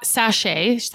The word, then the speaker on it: sachet: When you purchase NeoPX, you will receive 6 power drop sachet. How do you say that? Sachet? – You got it.